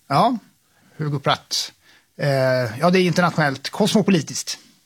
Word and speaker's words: Hugo Pratt: Och inte minst dess skapare Hugo Pratt, har jag alltid sagt. Men så kan jag ju rimligtvis inte uttala det själv. – Jag vet inte. Alltså, vi säger Hugo Pratt.